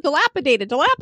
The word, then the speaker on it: dilapidated: I totally know dilapidated.